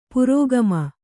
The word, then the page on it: ಪುರೋಗಾಮಿ: purōgāmi